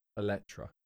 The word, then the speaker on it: Eletre: Sorry, listeners, if you're at home, it's spelled E L Oh, E-L-E-N-T-R-E, I believe. Elentra. – E-L-E-N. – Sorry, E-L-E-T-R-E. Elettra.